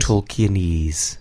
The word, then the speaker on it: Tolkienese: is that how that's pronounced? Tolkienese. – Tolkienese.